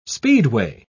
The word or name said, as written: speedway